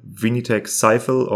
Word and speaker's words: Vinitech Sifel: It is spelled V-I-N-I-T-E-C-H-S-I-F-E-L. Vinitech Sifel, or how you pronounce it in français, Vinitech Sifel.